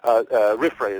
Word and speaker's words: RFRA: RFRA is the way the Religious Freedom Restoration Act abbreviation is usually pronounced.